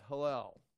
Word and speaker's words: Hillel: S-H-A-M-M-A-I, Shammai, and then Hillel. Did I spell that right? H-I-L-L-E-L.